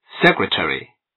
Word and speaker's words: secretary: 二，最后一个重读音节之后的非重读音节语调要低频，比如 She is our league secretary。这句中最后一个单词 secretary 的第一个音节是最后一个重读音节，所以朗读的时候语调要下降，而其后的非重读音节语调要低频。